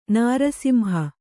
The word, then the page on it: ನೃಸಿಂಹ: nř simha